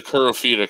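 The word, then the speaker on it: Kirifuda: I think it's pronounced Kira Fuda clutch. And of course, it's the Kira Fuda clutch.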